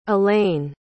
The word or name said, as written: aleyn